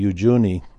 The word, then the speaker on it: Uyuni: There's a dry lake bed there, kind of like our Salt Lake in the United States, but it's called Lake Ujuni, I believe it's pronounced. And it's spelled U-Y-U-N-I is the way it's spelled. But in Spanish, it would be Ujuni.